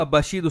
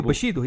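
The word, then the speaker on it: بوشيدو (武士道): لا أيوة لا باليو بوشيدو هي بوشيدو زي ما قلت كذا بوشيدو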